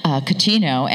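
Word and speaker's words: Cottineau: Julie Catino, and Catino is spelled C-O-T-T-I-N-E-A-U, a little French twist.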